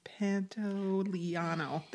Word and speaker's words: Pantoliano: Pantoliano.